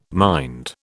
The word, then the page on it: mind: British English